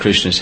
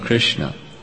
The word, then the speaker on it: Krishna: What is this name of Krishna? It's two syllables, Krish and Na. I mean, if you remember from yesterday, Krish, Jiva Goswami breaks it down. In Krishna Sandarbha, he breaks it down.